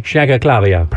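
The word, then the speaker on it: Shaykhlislamova: Say it again. Say her name again. – Shank Clavia. Shankaklavia. – Do it again. – Irina Vararena Shankaklavia. – Perfect. You got it. – That's exactly right.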